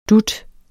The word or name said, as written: dut